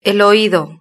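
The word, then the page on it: el oido: Spain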